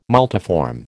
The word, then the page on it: multiform: American English